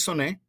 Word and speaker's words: Oné: My first name is Soné. It's spelled just like the number one in English. It has a little accent on the E.